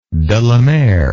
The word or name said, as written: de la Mare